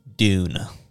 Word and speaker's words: dune: But one word that I think makes complete sense is dune. Dune, as in Because like if we think about it, right? June is like June, July, August, you know, it's a month, right? It's spelled with a J. – And then we say June the same way when it's with a D.